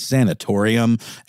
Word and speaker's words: sanatorium: By the way, I was pronouncing the word wrong. It is sanatorium.